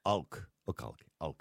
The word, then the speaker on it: Aouilk: Aouilk.